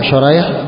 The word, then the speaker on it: شريح: شريح بالشين المعجمة والحاء المهملة